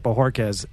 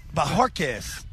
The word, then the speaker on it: Bohorquez: He forgot the R. There is an R in the name. I am now siding with Patriots Media Relations. I now accept Bohorquez as the acceptable pronunciation. – Bohorquez.